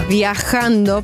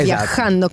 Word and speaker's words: viajando: Tutto bene al 105, ma non sapete che la pronuncia corretta è questa. – Viajando por el mundo. Esatto. Viajando con la J, la sì, sì. Bisogna avere un po' di catarro praticamente.